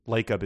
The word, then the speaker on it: Lacob: Lacob is spelled Jacob, but with an L.